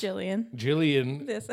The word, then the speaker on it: Gillian: Jillian. – I thought it was Gillian. – Jillian. – Oh, what about Gillian Anderson? Anyways, Jillian of the Spirits. – It's pronounced Jillian for her, too.